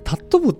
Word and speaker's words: 尊ぶ: タットビっていう名前もちょっとこだわりがとってもありまして、尊ぶってタットブって読むんですよね。